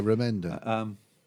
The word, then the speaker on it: Remender: Remender. Remender. – Yeah, Remender.